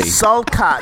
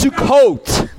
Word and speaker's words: Sukkot: Selkat.